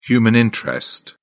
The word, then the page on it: human interest: British English